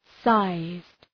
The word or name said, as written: sized